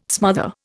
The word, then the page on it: smother: Indian